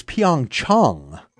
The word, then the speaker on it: Pyeongchang: And anyway, on to Pyeongchang, or the proper pronunciation evidently is Pyeongchang.